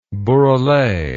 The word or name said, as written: burele